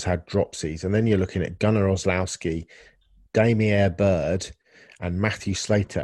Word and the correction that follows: Olszewski: and Gunnar, in fact, I've said his name wrong. Gunnar Olszewski is the fifth receiver.